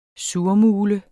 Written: surmule

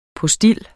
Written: POSTIL

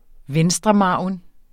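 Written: venstremargen

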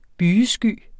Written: bygesky

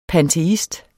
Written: panteist

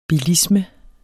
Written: bilisme

